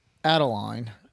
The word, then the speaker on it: Adeline: I think I said it's Anlesley, and it's actually Adeline. So messed up on that one. If you listen, brother-in-law or sister-in-law, apologies. Did not mean to get the name wrong. – It's like Madeline, but without an M.